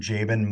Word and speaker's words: Jaban: Yeah, uh, if you want to find me, you just look up Dr. Jaban Moore. Um, my name is spelled J A B as in boy A N. So Dr. Jaban Moore.